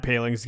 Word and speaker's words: Poling: Ryan Poling is the pronunciation there. Uh, pa- Paling. Really thought about that. Paling, Ryan Paling.